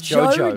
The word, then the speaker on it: JoJo: JoJo, but what song? Why did I say it like that? It's JoJo.